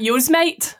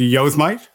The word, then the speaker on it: Yosemite: I'm just saying, I've been saying that wrong my whole life. I call that Yosemite. That's what it looks like. Anyway, sorry, keep going. I was like, that's how you say it.